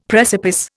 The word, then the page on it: precipice: Indian